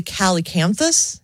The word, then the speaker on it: calycanthus: But the calycanthus, am I saying that right? – Yeah.